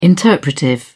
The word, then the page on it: interpretive: British English